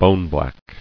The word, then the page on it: Boneblack: bone·black